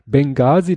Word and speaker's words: Benghazi: Also es gab nämlich eine Geschichte in Benghazi und das wird im Englischen nämlich mit H gesprochen. – Mhm. äh, geschrieben, aber nicht gesprochen.